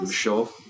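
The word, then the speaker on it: Michôd: By David Michaud, I think his name is? – Michaud. – I don't know. Michaud? Okay. – Yeah, yeah. They French people tend to not pronounce the last consonants.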